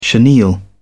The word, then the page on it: chenille: British English